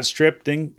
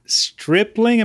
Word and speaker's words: Stripling: We have a privilege today to have with us a friend of a friend whose name is Scott Stripling. Scott Stripling, am I saying that right, Scott? – You nailed it.